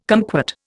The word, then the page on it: kumquat: Indian